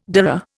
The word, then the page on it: dhurra: Indian